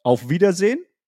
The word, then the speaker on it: Auf Wiedersehen: Auf Wiedersehen. Let's go. – Wait, I can't get the last syllable. Auf Wiedersehen. – Auf Wiedersehen.